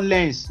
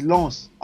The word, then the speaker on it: Lens: It's not Lens. It's Lens. RC Lens.